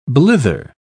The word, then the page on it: blither: British English